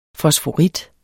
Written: fosforit

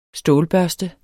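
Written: STÅLBØRSTE

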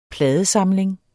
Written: PLADESAMLINGER

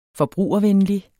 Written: forbrugervenlig

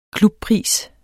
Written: klubpris

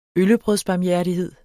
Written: øllebrødsbarmhjertighed

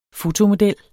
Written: fotomodel

